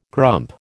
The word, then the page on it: grump: American English